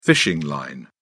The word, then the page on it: fishing line: British English